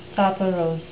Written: soporose

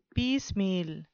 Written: piecemeal

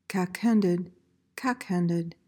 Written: cack-handed

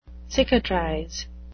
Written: cicatrize

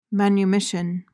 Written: manumission

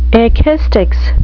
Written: ekistics

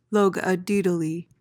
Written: logodaedaly

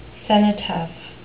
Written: cenotaph

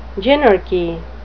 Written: gynarchy

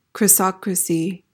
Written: chrysocracy

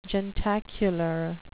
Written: jentacular

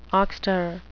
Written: oxter

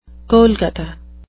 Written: golgotha

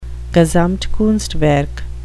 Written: gesamtkunstwerk

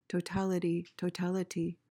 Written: totality

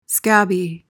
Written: scabby

